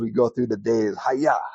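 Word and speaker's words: ha-yah (הָיָה): Let there be in Hebrew is ha-yah, is how you pronounce it.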